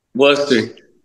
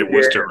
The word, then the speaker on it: Worcester: Say it. – Worcester. – Close. No. We're getting there.